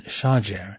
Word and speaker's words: Shajer: Quote, the combination Shajer is quite common in Palestinian place names. It is a collective meaning trees, and many Arabs, especially in Egypt, pronounce it as Shajer.